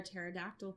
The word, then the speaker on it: pterodactyl: Why can't you hear a pterodactyl go to the bathroom? – Why? – Because the P is silent.